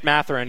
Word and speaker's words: Mathurin: Oh, Benedict Mathurin, yeah, from Arizona.